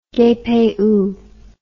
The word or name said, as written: gaypayoo